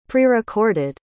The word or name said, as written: prerecorded